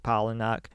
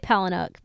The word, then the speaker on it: Palahniuk: She titled her post, quote, "You're always haunted by the idea you're wasting your life," after a quotation from novelist Chuck Palahniuk. She used that quote, I never know how to say his name right. – Please, you said it right.